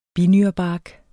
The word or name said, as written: binyrebark